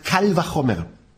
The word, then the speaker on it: kal vachomer: Auf Hebräisch, wer es hören will, nur mal ausgesprochen, heißt kal vachomer.